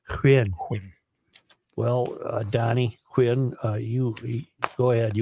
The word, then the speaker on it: Nguyen: Nguyen, is it Nguyen? – Nguyen. Yeah.